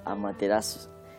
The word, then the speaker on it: Amaterasu: Bueno, ya sabéis que se pronuncia casi con toda seguridad Amaterasu.